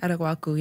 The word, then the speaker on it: Araguaco: Did I say it right? – Araguaco, yeah. It's okay. Like guac.